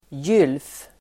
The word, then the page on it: gylf: [jyl:f]